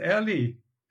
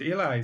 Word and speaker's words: Eli: And you even pronounced Ellie and not Eli.